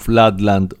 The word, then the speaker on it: Floodland: το άλμπουμ Floodland, όπως μας διόρθωσε ένας φίλος της εκπομπής των Sisters of Mercy, η σωστή του προφορά δηλαδή, να ξεκινήσουμε την εκπομπή μας, φίλες και φίλοι.